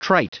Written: trite

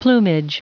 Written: plumage

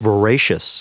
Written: voracious

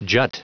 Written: jut

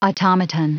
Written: automaton

